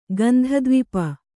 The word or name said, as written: ಗಂಧದ್ವಿಪ